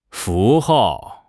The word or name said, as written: 符号